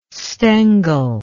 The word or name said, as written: Stengel